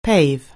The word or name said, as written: pave